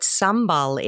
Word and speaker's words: sambal: For our listeners who may not know what sambal is, and am I saying it correctly? – Yes, you're saying it correctly. Sambal is how I say it. That is correct.